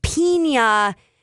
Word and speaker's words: piña: Yeah, but piña, if it's an N with the squiggle over the top, that is pronounced with the yay. Iña.